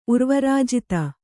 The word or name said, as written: ಉರ್ವರಾಜಿತ